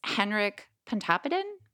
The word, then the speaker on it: Pontoppidan: Okay, and the author is Henrik Pontoppidan? Is that good? – That sounds good.